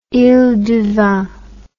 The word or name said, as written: iles du vent